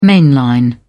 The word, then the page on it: mainline: British English